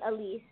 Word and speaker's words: Elysee: Because my mom actually was off of the Champs Elysee, but we say Elysee since we're here in America over here. People pronounce it Elysee.